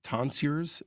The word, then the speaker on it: tonsures: their hair worn in partially shaved tonsures. Is that how you pronounce that? – I think it is, yeah.